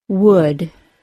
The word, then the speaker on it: would: There are three words, could, should, and would, that are pronounced with the other U sound that are spelled O-U-L. In these words, there is no L sound, and the vowel sound is the other U sound. In other English words spelled O-U-L, it should be assumed that the vowel sound is not the other U sound, and that the L sound is also necessary.